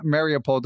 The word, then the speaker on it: Mariupol: You're from Mariupol.